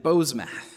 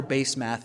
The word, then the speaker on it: Basemath: I had to look up the Hebrew for how to pronounce that name, by the way. It's kind of an odd name. You can call her Basemath if you want, because that's what it looks like.